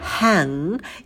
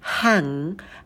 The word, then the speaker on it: hang: Потом у нас идет звук лягушка «hang» и носовой звук «н». Звук «г» мы не произносим, у нас носовой «н».